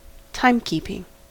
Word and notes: timekeeping: American English

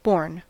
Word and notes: born: American English

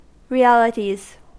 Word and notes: realities: American English

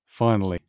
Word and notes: finally: British English

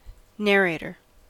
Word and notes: narrator: American English

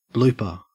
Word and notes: blooper: Australian English

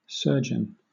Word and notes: surgeon: British English

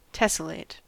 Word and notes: tessellate: American English

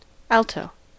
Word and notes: alto: American English

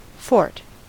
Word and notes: fort: American English

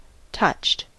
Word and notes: touched: American English

